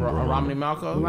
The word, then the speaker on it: Romany Malco: Romany Malco.